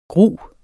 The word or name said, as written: grus